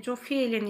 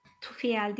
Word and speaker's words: Jofiel: Bir tek bir e, Jofiel'in ismi farklı. J ile yazılıyor. Jofiel diye okunuyor. Onun dışındakilerin hepsi orada yazıldığı gibi okunuyor. Meltem Karanfil dişilik aktivasyonu için bu melekle ha Jofiel demiştim. Tufiel değil, Jofiel, baş melek Jofiel ile çalıştığınız zaman az önce söylediğim J ile yazılıp Jofiel diye yazılıyor.